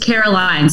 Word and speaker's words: Caroline: It's just Caroline. Sweet Caroline, Caro freaking line.